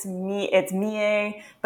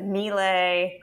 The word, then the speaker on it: Millet: I think it's, if it's French, it's Millet, but apparently if you were Norman, you might have said Millet.